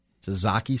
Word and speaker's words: tzatziki: Good Euros, great chicken, kebab, and the best tzatziki sauce.